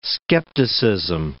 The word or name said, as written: skepticism